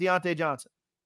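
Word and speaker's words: Diontae Johnson: who is it and why is it Deontay Johnson? – Yeah.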